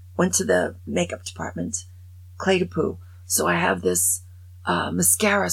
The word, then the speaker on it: Clé de Peau: Okay, I learned how to pronounce it. Clé de Peau.